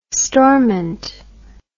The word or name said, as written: Stormont